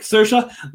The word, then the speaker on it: Saoirse: once I learned, I talk about this a lot, but once I learned Saoirse Ronan on a podcast, said like Saoirse, like inertia, and it's changed my way to pronounce everything now, not just her name.